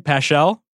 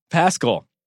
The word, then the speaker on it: Paschal: They just got obliterated by the Warriors G League team on national TV, in which our guy, Eric Paschal. Am I pronouncing that right? Paschal? – Pascal.